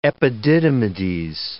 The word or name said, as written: epididymides